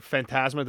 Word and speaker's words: Fantasma: Or Fantasma, did I say his name correctly? – That's right.